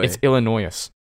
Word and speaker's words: Illinois: You do not pronounce the S. You guys. – Illinois. Oh Illinois. – Camden. Illinois. It's Illinois.